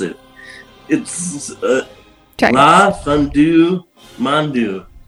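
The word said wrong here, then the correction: La Fin du Monde: It's a beer they have there. – A fin du monde.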